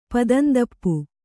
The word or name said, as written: ಪದಂದಪ್ಪು